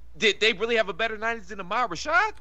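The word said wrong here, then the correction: Ahmad Rashad: Ahmad Rashad no, couldn't pronounce Ahmad Dwayne was...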